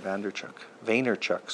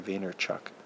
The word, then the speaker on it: Vaynerchuk: I wanted to continue on my topic of Gary Vaynerchuk. Sorry, I realized I pronounced it wrong. It's Gary Vaynerchuk.